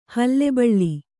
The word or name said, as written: ಹುಲಿಬಲೆ